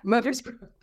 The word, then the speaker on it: Murfreesboro: Murfreesboro.